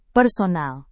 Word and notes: personal: Indonesian

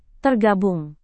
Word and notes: tergabung: indonesian

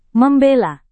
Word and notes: membela: Indonesian

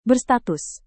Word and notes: berstatus: Indonesian

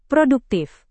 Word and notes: produktif: Indonesian

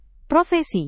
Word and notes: profesi: Indonesian

